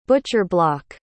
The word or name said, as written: butcher-block